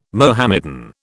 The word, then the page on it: Mohammedan: British English